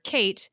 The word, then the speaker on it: Ceit: Now, lest any of you think she's gone straight by naming her main character Kate, it's spelled C-E-I-T.